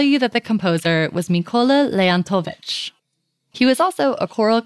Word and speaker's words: Leontovich: Leontovich.